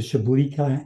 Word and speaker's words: Chablis: And I see here it's matured in Chablis wine casks. Am I saying that correctly? – Chablis wine cask at the very end.